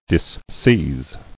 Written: Disseize

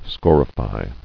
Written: Scorification

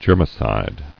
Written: Germicidal

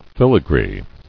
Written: Filigree

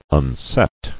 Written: Unset

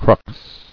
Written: Cruxes

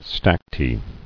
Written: Stacte

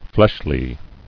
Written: Fleshly

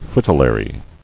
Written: Fritillary

